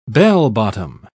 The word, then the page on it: bell-bottom: British English